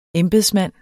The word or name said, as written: embedsmand